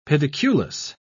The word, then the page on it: pediculous: British English